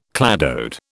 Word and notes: cladode: British English

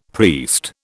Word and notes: priest: British English

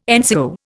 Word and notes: NCO: Indian

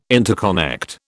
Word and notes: interconnect: British English

